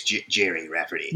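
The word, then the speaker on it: Gerry Rafferty: I think it's Jerry Rafferty. – You think it's Jerry? – I've always pronounced it as such. So it's like Jerry with a G instead of a J.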